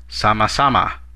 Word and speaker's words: sama-sama: Another way, this time a more informal way, of responding to a phrase of gratitude is sama-sama. Sama-sama. Let's break it down by syllable. Sa-ma-sa-ma. Ma. Now let's hear it once again.